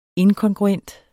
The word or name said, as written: inkongruent